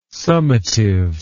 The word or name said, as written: summative